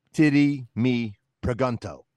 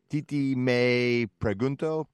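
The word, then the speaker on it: Tití Me Preguntó: So in Ohio, they would say, titty me pregunto. – Right. – But that's not how it's pronounced, right? Because it's Spanish. So it's more like something like titi me pregunto.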